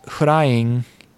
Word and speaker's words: Hulaing: Okay, because I was like Hulaing, and then they start saying it, and you're like, oh, Hulaing is pronounced Hulaing, which is the same pronunciation as flying.